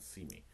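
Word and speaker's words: Syme: I actually went and looked up to pronounce that Seamy.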